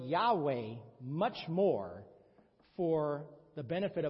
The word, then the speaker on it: Yahweh: The English pronunciation is Yahweh.